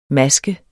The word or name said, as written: MASKENS